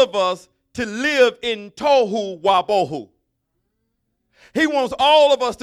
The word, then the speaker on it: tohu wa bohu: But the word void is bohu, B-O-H-O-O. Somebody say boho. So it's tohu and bohu. That's how you pronounce it. So in the Hebrew, it would be pronounced tohu wa bohu. That's how you would say those two words in Hebrew, tohu wa bohu.